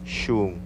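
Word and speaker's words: xun: Pronuncia shum.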